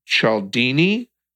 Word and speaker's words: Cialdini: And this is by noted author Robert, I think you pronounce it Cialdini, C-I-A-L-D-I-N-I, or Cialdini.